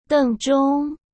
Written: dengzhong

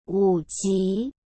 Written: wuji

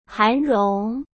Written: Han Rong

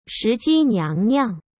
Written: Shiji Niangniang

